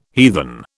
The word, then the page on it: heathen: British English